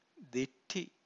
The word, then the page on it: ditthi: Pali